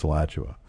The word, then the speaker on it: Alachua: I'm going to say Alachua because I freaking looked up how to pronounce it. – Good. – Alachua. I didn't know it was. Is that Alachua? No, it's Alachua. – Good. I'm impressed.